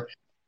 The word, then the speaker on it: haptic: One of them is hepatic, I think is how you say it, hepatic feedback, whatever, haptic.